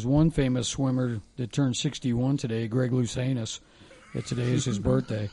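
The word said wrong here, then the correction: Louganis: That's Luganus. It's Luganus.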